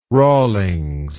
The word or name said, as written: rawlings